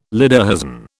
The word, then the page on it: lederhosen: British English